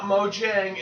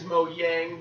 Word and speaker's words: Mojang: It is not Mojang. It is Mojang because the J is a soft J or some shit.